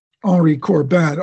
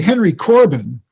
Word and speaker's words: Henri Corbin: Just to put them in context, if anybody does look at them, the first one was specifically about Henri Corbin.